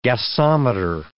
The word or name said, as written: gasometer